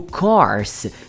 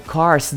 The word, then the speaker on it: curse: O course, que significa curso, e o curse, que todo mundo pronuncia como curso, mas que significa maldição.